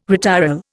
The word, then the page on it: retiral: Indian